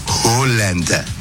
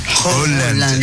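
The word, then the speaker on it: Haaland: La doble A. – Sí. – En noruego es Holland. – Ya. – ¿Cómo? – Holland. – Ya, te lo encargo, vamos. – Holland.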